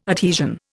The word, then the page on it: adhesion: Indian